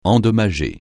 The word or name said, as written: endommager